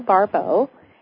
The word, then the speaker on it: Barbeau: Okay, it's my name, Carol Barbeau, and my last name is spelled B-A-R. B-E-A-U, like you go to a bar to get a bow.